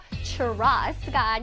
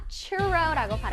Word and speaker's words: 추로: 추로스가 아니라 추로라고 발음하는 거꼭 기억할게요.